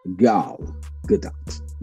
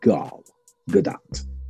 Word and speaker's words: Gal Gadot: Movie actress and the official Wonder Woman, Gail Gadot. Gal Gadot. Yes, I had to say it. I had to pronounce that. Gal Gadot.